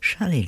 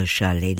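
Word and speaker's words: Charlélie: Charlie, unusual name, C-H-A-R-L-E, accent on the E, L-I-E. Charlie, he's actually a French-American, but mainly French, electronic, multidisciplinary artist.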